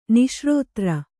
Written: ನಿಶ್ರೋತ್ರ

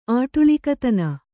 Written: ಆಟುಳಿಕತನ

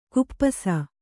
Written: ಖಪ್ಪಸು

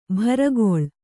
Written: ಬಿರುಗಾಳಿ

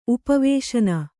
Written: ಉಪವೇಶನ

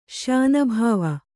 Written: ಶ್ಯಾನಭಾವ

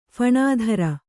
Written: ಫಣಾಧರ

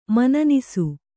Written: ಮನನಿಸು

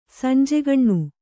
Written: ಸಂಜೆಗಣ್ಣು

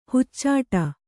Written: ಹಚ್ಚಿಡು

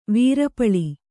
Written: ವೀರಪಳಿ

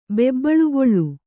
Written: ಬೆಬ್ಬಳುಗೊಳ್ಳು

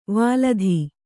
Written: ವೇಲ್ಲಿತ

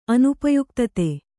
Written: ಅನುಪಯುಕ್ತತೆ

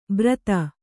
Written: ಬ್ರತ

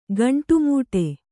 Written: ಗಂಡುಮೆಟ್ಟು

